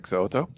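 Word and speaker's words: So'oto: And you got his name right, Vic Siloto.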